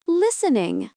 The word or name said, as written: listening